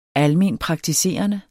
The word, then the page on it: almenpraktiserende: [ ˈalˌmeˀnpʁɑgtiˌseˀʌne ]; [ ˈalˌmeːn- ]